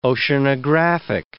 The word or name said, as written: oceanographic